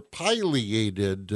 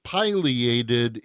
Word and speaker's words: pileated: Both pronunciations are correct. If you look in the dictionary, it tells you that pileated is the preferred pronunciation. But in this part of the world, most people refer to them as pileated.